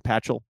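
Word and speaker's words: Paschal: Josh, what is Patchell? Am I saying that correctly? – Yes.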